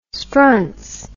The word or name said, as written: strunz